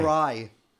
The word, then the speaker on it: Mariah: Why? How do you say it? – Mariah. – Mariah. – There's no A. – There's no at the end. Just Mariah.